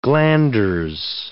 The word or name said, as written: glanders